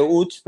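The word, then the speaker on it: Łódź: Sí, Se, dice, bueno, como se dice Uch, pero se escribe LOZ.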